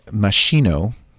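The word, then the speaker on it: Moschino: Meanwhile, the clothing company Machino... Am I saying it right, Ryan? – Yeah.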